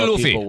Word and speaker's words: Luffy: It's Luffy, right? Monkey D. – I'm Luffy. – Watching the game now. – Monkey D. Luffy.